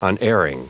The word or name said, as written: unerring